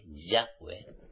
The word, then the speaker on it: Yahweh: Si tenéis verdaderamente el espíritu de Yahweh, clamar al Padre Yahweh, pronunciando bien su nombre, Yahweh, en el nombre de Yahshua, y veréis cómo el espíritu de Yahweh pondrá en contacto personalmente conmigo.